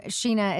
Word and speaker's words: Scheana: My name, Sheena, is spelled S-C-H-E-A-N-A.